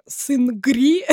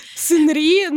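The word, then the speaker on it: сынри (승리): А, потому что раньше очень мало было знатоков корейского языка, и в основном все переводили с английского, а по-английски он пишется как сынгри, сынри, ну, в общем, так и повелось.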